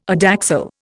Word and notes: adaxial: Indian

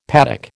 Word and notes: paddock: American English

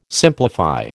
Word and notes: simplify: American English

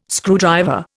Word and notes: screwdriver: Indian

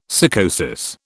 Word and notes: sycosis: British English

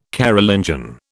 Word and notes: Carolingian: British English

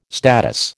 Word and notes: status: American English

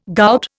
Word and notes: gout: Indian

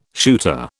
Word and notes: shooter: British English

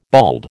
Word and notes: bald: American English

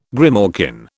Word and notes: grimalkin: British English

